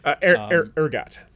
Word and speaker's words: ergot: I have a feeling that is probably the ergo. – Ergot. – Ergot. – Yeah, that one is not pronounced. – I was on the French kick. – Yeah, it's not pronounced all Frenchy.